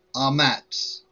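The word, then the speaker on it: Amatz (אָמֵץ): Its pronunciation, the translation and pronunciation is Amatz. And this is a Hebrew word.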